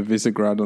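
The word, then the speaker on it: Visegrád: There's the last climb to Visegrad on, I hope I've pronounced that more or less correctly. – Yeah, you pronounce right.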